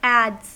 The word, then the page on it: adze: American English